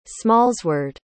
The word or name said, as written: smallsword